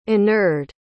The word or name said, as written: inured